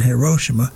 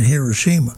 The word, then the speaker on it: Hiroshima: So I hit one of them, and it said Hiroshima. I hit another one, said Hiroshima. – Well, there you go. – So take your pick.